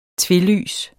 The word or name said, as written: tvelys